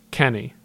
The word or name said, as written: Kenny